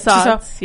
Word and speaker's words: Tussauds: Tussauds, yeah.